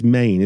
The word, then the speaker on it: Men: It's ENYS MEN, isn't it? – Yeah, so it's as if it's spelled M-A-N-E. – Yeah. – So it comes from the Cornish word menhir, which means standing stone or long stone. And okay. Main being the first syllable meaning stone.